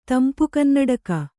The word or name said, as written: ತಂಪುಕನ್ನಡಕ